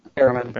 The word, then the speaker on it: Barrowman: How do you say his name? Barrowman? – Barrowman.